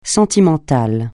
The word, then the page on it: sentimental: francais